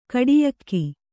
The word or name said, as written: ಖೋಡಿಯೋಗು